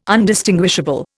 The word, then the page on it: Undistinguishable: Indian